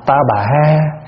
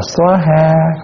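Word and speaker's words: svāhā: Thì cái chữ sa ha có khi đọc là ta bà ha, có khi đọc là xóa ha, có khi đọc là tóa ha, đọc sao cũng được, phiên âm mà.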